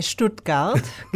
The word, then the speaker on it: Stuttgart: Vos sos de Stuttgart, pero pronuncialo bien porque a mí me sale bastante mal. – Sí, de Stuttgart, que es una capital de Estado Federado de Alemania.